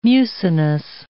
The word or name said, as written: mucinous